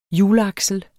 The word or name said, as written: hjulaksel